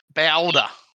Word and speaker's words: BALDR: Boulder. – We have to say it like a Queenslander. That's Boulder.